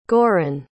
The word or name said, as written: goran